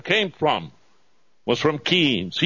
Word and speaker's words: Keynes: K-E-Y, key. I know he liked to say, it rhymes with brains, but it doesn't. Keynes didn't have a brain.